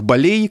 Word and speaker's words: болей: Я бы здесь хотел добавить, что ударение болей зафиксировано в словарях русского языка, зафиксировано именно с пометой «мед», то есть медицинская, Словари отмечают этот вариант и особо оговаривают, что это а, вариант профессиональный, характерный для речи тех, кто профессионально связан с медициной.